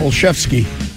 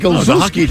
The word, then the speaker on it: Olszewski: What's the correct way of pronouncing it? – Olszewski. – All right. – But it looks like Olszewski, doesn't it? It looks like that.